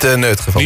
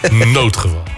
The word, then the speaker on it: noodgeval: Noodgeval, Joris, zo spreek je daar. – Niet neutgeval. – Niet neutgeval, noodgeval.